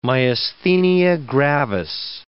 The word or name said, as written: myasthenia gravis